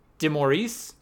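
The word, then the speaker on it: DeMaurice: Player Association, an executive director, DeMorris. Am I pronouncing that correctly? – Yeah.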